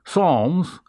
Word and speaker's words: Psalms: And why did anyone pronounce the word Psalms as if their letter P was in it? Okay? Why was even letter that there? You didn't use the letter P, you just said Psalms.